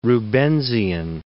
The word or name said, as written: Rubensian